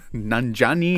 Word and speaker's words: Nanjiani: The film Big Sick stars Kumail Nanjiani. He is best, I pronounced it like an Italian Guido there, but Nanjiani.